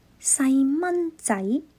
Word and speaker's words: 细蚊仔: 再给大家举个例子，细蚊仔，细蚊仔。细就是仔细的细，蚊这个字的发音在粤语里面跟蚊子的蚊或者是蚊帐的蚊是一样的，所以我们就用这个蚊，蚊虫的蚊，就是左边虫字旁，右边是文化的文，这个来表示蚊，细蚊。